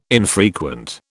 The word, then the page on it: infrequent: British English